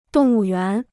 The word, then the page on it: 动物园: dòng wù yuán